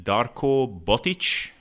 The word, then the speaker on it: Darko Botic: So I have on Darko Botic.